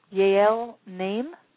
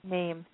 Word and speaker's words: Yael Naim: That's called New Soul by, and I think I want to pronounce this correctly, but I'm not sure, Yael Naim? – Yeah. – Name? Y-A-E-L-N-A-I. Name, yeah.